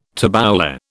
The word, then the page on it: tabbouleh: British English